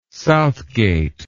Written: Southgate

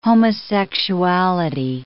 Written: homosexuality

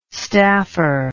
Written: staffer

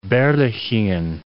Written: Berlichingen

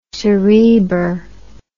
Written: cerebr

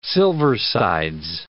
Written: silversides

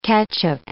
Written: catch-up

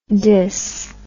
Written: dys

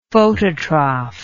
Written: phototroph

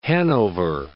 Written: Hanover

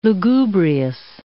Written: lugubrious

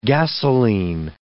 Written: gasoline

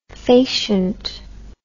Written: facient

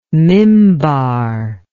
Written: mimbar

